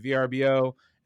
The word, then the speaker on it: Vrbo: I think it's like 50-50 the way people pronounce it. I still say VRBO. – They had like an ad. So they had a whole ad that was like, it's Vrbo.